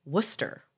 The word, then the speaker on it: Worcester: It's actually Worcester, which doesn't even make sense. I'm sorry. No. – That's it. That's how you say it.